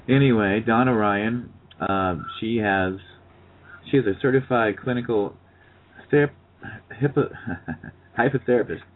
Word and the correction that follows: hypnotherapist: How do you say that? – Certified clinical hypnotherapist.